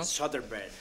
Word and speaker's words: Soderbergh: ¿Cómo se dice? ¿Cómo Steven Soderbergh, ¿no? – Soderbergh.